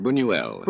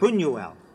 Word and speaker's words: Buñuel: And now, after 15 years of more conventional pictures, you've made a movie with another movie giant, Luis Buñuel. Is that how you say it? – Buñuel. – Buñuel.